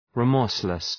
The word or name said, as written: remorseless